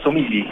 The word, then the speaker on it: Somigli: Casi, casi, como lo dijiste. En realidad se pronuncia Somigli. Es una particularidad del idioma. En realidad yo siempre le digo Somigli porque es para simplificar el asunto. Así que dejémoslo en Somigli nomás.